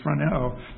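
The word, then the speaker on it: phroneō: It's phroneo. If you were going to spell it in English, it would be P-H-R-O-N-E, and then a long O at the end, which is the letter omega, the last letter of the Greek alphabet.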